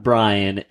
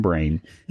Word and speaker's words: Brain: It's not Brian, it's Brain. – Yes. So don't get his name wrong, and then everything will just be fine. – Yeah. – You'd be a very good leader. Just make sure you call him Brain.